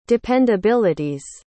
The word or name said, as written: dependabilities